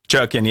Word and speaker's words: Chutkan: judge, I think her name's pronounced Judge Chutkan or something like that. – Chutkan, yeah.